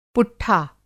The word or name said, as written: पुट्ठा